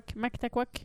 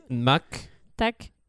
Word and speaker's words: Mactaquac: MacTaquac? – Oui. – Ok, j'ai ça, galéré c'est... à la prononciation, je suis désolé. – MacTaquac.